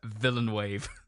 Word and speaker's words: Villeneuve: Villain wave.